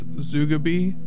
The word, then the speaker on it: Zugabe: Before that, you heard the only way I can think to pronounce this band's name is Zugabe. Z-U-G-A-B-E.